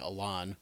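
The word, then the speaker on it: Alain: This guy named, I think it's pronounced like Alan. It's A-L-A-I-N.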